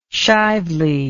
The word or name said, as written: Shively